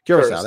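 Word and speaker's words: Curaçao: How do you say it? Curacao? I never even pronounced it right. Curacao, that's it.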